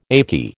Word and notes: achy: American English

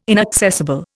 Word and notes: inaccessible: Indian

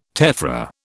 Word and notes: tephra: British English

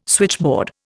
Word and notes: switchboard: Indian

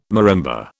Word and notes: marimba: British English

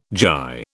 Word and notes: gyre: British English

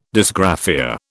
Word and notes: dysgraphia: British English

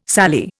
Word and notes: sallee: Indian

